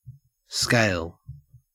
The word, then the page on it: scale: Australian English